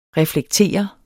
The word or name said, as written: reflektere